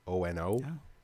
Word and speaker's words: Maono: It looks like Moana, but it's not Moana. It's like Maono.